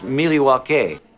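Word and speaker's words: Miłosławski: And and we're going to talk about the cider Miloslawski from Brower Fortuna in Miloslaw, Poland. – Actually, it's pronounced Miliwake.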